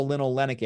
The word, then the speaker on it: linolenic: There's only two of them, linoleic acid here and then ALA, alpha linolenic acid. There's a little N in the second part of the name. And so that's how you say it different. This has no N there.